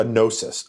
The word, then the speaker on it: gnosis: they create a gnosis, with silent G, like Gnostic, where they have the secret knowledge.